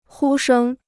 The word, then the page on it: 呼声: hū shēng